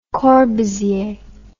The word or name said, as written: Corbusier